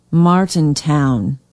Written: martintown

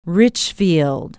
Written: richfield

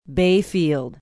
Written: Bayfield

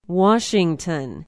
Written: washington

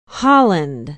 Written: holland

